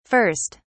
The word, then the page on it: 1st: British English